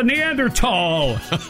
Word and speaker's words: Neanderthal: There's something special about a man older than dirt calling me a Neanderthal. Really, me? – You pronounce it right.